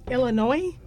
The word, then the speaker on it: Illinois: Anybody know how to say, this is a test, how do you pronounce the state that Chicago's in? – Illinois? – Thank you. Right. Thank you. – Illinois. – If I hear one more S.